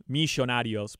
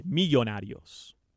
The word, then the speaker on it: Millonarios: Yeah, you kept saying Millonarios, but when it's a double L, you don't have to pronounce it like I would, Millonarios, but it's a Y, Millonarios.